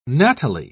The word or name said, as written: nattily